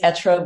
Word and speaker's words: être: And the pronunciation is perfect. The word is être, which is the French verb to be. And I should be pronouncing it with so much more French flair, but we are not.